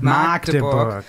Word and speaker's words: Magdeburg: Von genau, Magdeburg spricht man nämlich mit kurzem A. Mit Magdeburg.